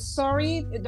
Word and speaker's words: Surrey: It's Surrey.